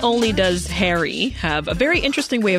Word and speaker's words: HXRY: H-X-R-Y. – Remember that, H-X-R-Y. – Which is pronounced Harry.